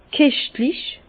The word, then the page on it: keschtlich: Bas-Rhin; Herrlisheim